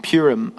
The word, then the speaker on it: Purim: Um, after the, uh, in, in, chapter 9, it talks about the festival of, of um, Purim. I think that's how you say it, P U R I M.